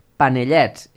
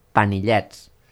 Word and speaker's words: panellets: Consisteix en un àpat en què es mengen castanyes, panellets, escrit amb E i no amb I, tot i que en alguns llocs s'hi pronuncia panillets, moniatos i fruita confitada.